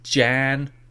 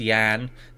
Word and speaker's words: Jan: Iris, Jan. Sorry, it's pronounced Yan. They actually make that clear. They put Yan underneath it, so the J is a hard J, soft J.